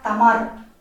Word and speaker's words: Тамар: Первый наш сегодняшний герой, первая наша героиня – царица Тамар, Именно в такой форме принято произносить в Грузии, такая более русская, русская форма Тамара, вы все прекрасно знаете.